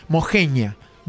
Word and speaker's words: Moxeña: Moxeña se escribe con X, pero la gente ahí lo pronuncia como Lo escriben con J o con G. Mojeña, dicen ellos.